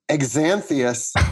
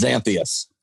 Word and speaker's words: Xanthius: Number three, Exanthius, which is his own creation. – It's actually pronounced Xanthius.